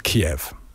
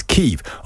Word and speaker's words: Kyiv: The Ukrainian pronunciation of Kyiv is Kyiv.